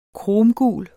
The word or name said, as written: kromgul